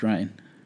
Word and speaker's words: Reyn: Rein or Ryan? – Rein, R-E-Y-N. Geyer. – Interesting. I think that's still Ryan. – Is it Ryan? – I believe it's pronounced Ryan.